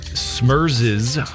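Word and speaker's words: Smerz: We'll get everything started with Smurzes. That's how you say it. Smurz, their version of Girls Just Want to Have Fun.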